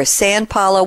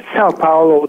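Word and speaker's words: São Paulo: Is it Sao Paulo or San Paulo? What's the correct pronunciation, please? – Sao Paulo would be a better fit.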